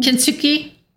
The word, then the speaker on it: Kintsugi: Kintsugi.